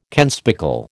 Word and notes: kenspeckle: American English

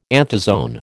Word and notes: anthozoan: American English

